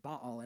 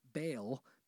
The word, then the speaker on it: Baal: This is why we pronounce it Baal and not Baal, because that would be an English reading of it.